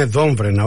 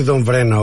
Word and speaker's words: Δόμβρενα: Άρα, για να το τονίζουμε σωστά, είναι Δόμβρενα, όχι Δόμβρενα όπως το βλέπω εγώ και δυστυχώς το λέω και εγώ λάθος τόση ώρα, κύριε Σπανέ.